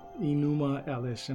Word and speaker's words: Enuma Elish: Enuma Elish. And I believe I'm saying that correctly, but if I'm not, it's spelled E-N-U-M-A-E-L-I-S-H.